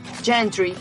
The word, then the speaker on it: gentry: Es una palabra que viene del inglés, gentry. Es alta burguesía, bueno, pronunciado bien en inglés, ¿no? – Gentry.